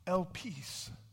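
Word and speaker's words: elpis (ἐλπίς): It's interesting because the Greek word for hope, and I know my pronunciation isn't perfect, but to the best of my understanding, it's pronounced elpis.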